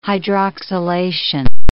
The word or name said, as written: hydroxylation